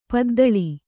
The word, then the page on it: ಪದ್ದಳಿ: paddaḷi